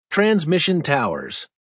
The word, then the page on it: transmission towers: American English